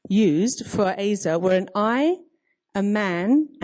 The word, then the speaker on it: Ezer: So just looking at the word helper, the Hebrew word is Ezer, E-Z-E-R, pronounced Azer, and it's a combination of two roots, meaning to rescue and to save, and strength and power.